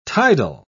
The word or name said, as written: tidal